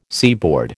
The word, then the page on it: seaboard: American English